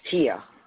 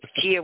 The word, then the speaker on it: Tia: Did I get your name wrong? – It's Tia. – Gia. I'm sorry, Gia. – No, it's Tia with a T, as in Tom.